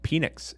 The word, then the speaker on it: Penix: I agree with Dave on the pronunciation of Penix. If there were two N's, then it would be Nix. It's like the difference between dinner and diner.